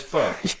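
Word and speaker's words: Bach: Whatever, how do you pronounce it? – J.S. Bark.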